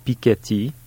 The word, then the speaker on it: Piketty: is whether one is going to say, Thomas Piketty,